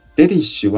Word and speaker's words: Relish: Relish は、楽しむとか味わうという意味なんですね。この単語は少し発音に注意が必要なんですけれども、アクセントは前に置きまして、 R と L がですね、連続するんですね。続きますので気をつけて発音しましょう。